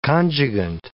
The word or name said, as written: conjugant